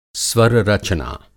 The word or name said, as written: स्वररचना